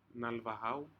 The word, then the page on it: naluahau: Aneityum